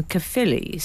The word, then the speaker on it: Caerphilly: My parents used to go to a club called the Double Diamond in Caerphilly, South Wales.